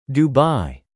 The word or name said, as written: Dubai